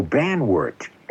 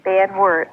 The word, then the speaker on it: Banwart: How do you spell that? – Banwert. – Oh, Banwart. – Banwart, uh-huh.